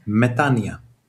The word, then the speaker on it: Metanoia: Alltså O-et uttalas inte alls. Metanoia. – No. O och en I become I. – O och I blir I. – Metanoia.